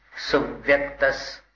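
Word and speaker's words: सुव्यक्तः: स्वसंवेदन सुव्यक्तस, कैसा क्या हो रहा है? सुव्यक्तस, बस स्वसंवेदन सुव्यक्तस, सुव्यक्तस, सुब इस पर जोर दीजिए, स्वसंवेदन सुव्यक्तस, तन्मात्रो निरत्ययः, अत्यंत सौख्यवानात्मा, लोकालोक विलोकनः।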